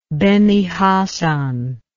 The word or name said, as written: Beni Hasan